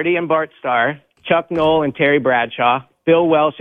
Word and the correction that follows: Walsh: and Joe Montana. – Bill Walsh. – My bad. Mispronunciation.